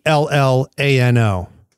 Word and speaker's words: Arellano: And the teacher was Michael Ariano. I think I said his name right. I tried to listen twice so I could make sure I said it right. – Yeah. Arellano.